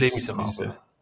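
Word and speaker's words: Les Misérables: Le Miserable geredet haben. Aha, ja. Mhm. Vom 2000, haben sie jetzt schön ausgesprochen? Le Miserable. – Les, Les Miserables.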